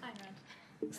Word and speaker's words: Ayn Rand: She is also an Ayn Rand Fellow. I think I've mispronounced that. – Ayn Rand. – Thanks.